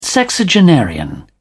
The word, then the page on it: sexagenarian: British English